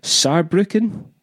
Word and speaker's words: Saarbrücken: And then in Germany, we're playing in Cologne, Hamburg, Berlin, Munich, Saarbrücken... Yeah. Am I saying that right? – Yeah.